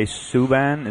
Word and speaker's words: Subban: I saw that P.K. Subban, is that how you say it? – Yep.